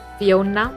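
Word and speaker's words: Phiona: You can search for Fiona Martin. So Fiona is spelled with a P-H-I-O-N-A.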